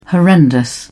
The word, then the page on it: horrendous: British English